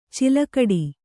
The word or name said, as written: ಛಲಗೇಡಿ